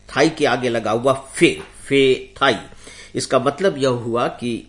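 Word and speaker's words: Phethai: इस तूफान का नाम है फेथाई, जिसके इंग्लिश में स्पेलिंग होगी PP for Patna, H for Haryana, E for England, फे THAI फेथाई।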